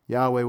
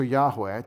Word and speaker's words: Yahweh: but we conjecture Yahweh to pronounce this name. And I want you to remember this is based on these three letters, H-W-H or H-V-H, or H-Y-H actually, which is the verb to be for existence.